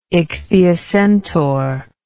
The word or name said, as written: ichthyocentaur